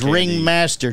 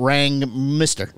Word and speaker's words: Ringmaster: This is Ringmaster, so you can pronounce this one? – Yes. – Not Rang Mister.